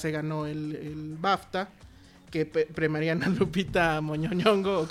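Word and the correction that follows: Nyong'o: ¿cómo se dice? – Oh, bueno, Ñongo. – Ñongo, eso.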